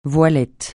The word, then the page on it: voilette: francais